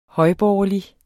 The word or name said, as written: højborgerlig